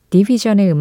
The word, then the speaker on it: DVSN: 이권삼님이 캐나다의 R&B 듀오 디비전의 음악 신청해 주셨는데요. 발음은 디비전이라고 하지만 쓰기는 DVSN 이렇게 간결하고 또스타일리쉬하게 표기를 합니다.